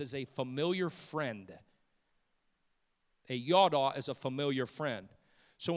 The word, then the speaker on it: yada: It's Y-A-D-A. Y-A-D-A, it's yada, yada. Like if somebody were to say yada, yada, yada, that's spelled the same way, yada, yada.